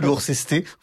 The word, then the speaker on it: Worcester: Elwood Worcester, Elwood Worcester, comme la sauce qui va sur le, oui, c'est...